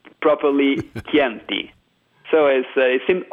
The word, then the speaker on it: Chianti: For example, in Latin America, it's even curious this kind of situation, because most of them before the academy used to pronounce Chianti like Shanti, like something that sounds more like French.